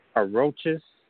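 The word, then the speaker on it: aurochs: is Aurochus. Aurochus is, and you spell it A-U-R-O-C-H-S. A-U-R-O-C-H-S.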